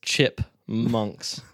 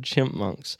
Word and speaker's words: chipmunks: No, it is chipmunks. I don't know why I said chimpmunks.